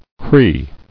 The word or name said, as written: Crees